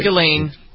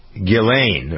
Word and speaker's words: Ghislaine: Is it Ghislaine? Is that how you pronounce it? Is that it? – Ghislaine. – Ghislaine, oh, mm-hmm. Silent S.